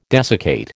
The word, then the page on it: desiccate: American English